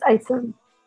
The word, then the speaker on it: Isom: It's Isom.